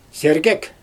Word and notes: sergek: Xyzyl

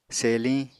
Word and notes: seliñ: Apatani

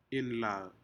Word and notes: inlac: Aneityum

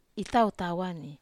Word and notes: tau tawa: Wamesa

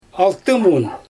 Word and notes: altymun: Xyzyl

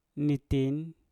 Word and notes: nitin: Mpur

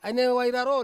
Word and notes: anewai raro: Ansus

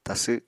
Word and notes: tasw: apatani

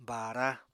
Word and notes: baara: apatani

